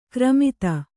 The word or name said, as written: ಖರಾಮತ್ತು